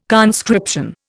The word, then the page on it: conscription: Indian